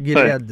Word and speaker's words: Gilead: Moi, je pense qu'il faut remonter à Gilead Science, c'est comme ça qu'on le prononce, docteur, hein? Gilead ouais.